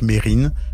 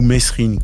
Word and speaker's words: Mesrine: Jacques Mérine, comme le prononcent ses proches, ou Mesrine, comme le disent de nombreux médias et policiers.